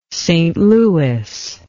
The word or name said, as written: St Louis